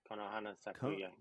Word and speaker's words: Konohana Sakuya: Konohana Sakuya.